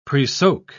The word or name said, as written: presoak